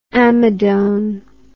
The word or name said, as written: amidone